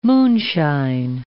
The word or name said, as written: moonshine